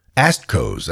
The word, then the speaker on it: ASTCOs: Well, they're ASTCOs, that's who. No, I did not just mispronounce the word assholes. The word I used is an acronym, A-S-T-C-O.